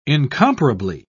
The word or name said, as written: incomparably